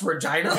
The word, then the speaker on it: Regina: So but it's pronounced Regina. – It's called Regina. Okay.